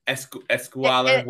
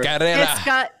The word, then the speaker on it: escalera: What's Escuela, whatever. – Escalera.